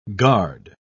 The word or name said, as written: guard